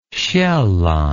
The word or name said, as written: sjaelland